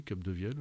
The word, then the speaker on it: Capdevielle: Et vous m'avez envoyé Salomé de Jean-Patrick Capdevielle. – Oui, je prononce bien. – Ah, bah oui, Capdevielle, oui.